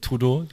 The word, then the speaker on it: Trudeau: Ähm, Justin Trudeau oder wie der ausgesprochen Trudeau. wird. – Trudeau, Justin Trudeau,